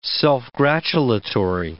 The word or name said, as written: self-gratulatory